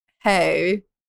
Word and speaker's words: Hej: I ended up going with the name Hey. Which it's spelled H-E-J.